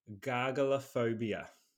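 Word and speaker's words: gargalaphobia: But talking about phobias, let me introduce you to gargalophobia. – Gargalophobia. – Yeah, and it is spelt exactly how it sounds. G-A-R-G-A-L-A phobia. Gargalophobia.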